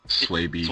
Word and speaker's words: Swaby: So, it's worst case, you know, it's me, the C-A-S-E, you know, and mm-hmm. Gary Swabby, that's how you say it, last name, Gary Swabby? – Swaby. – Swaby. – Yeah, everyone says Swabby, and everyone says don't worry about it.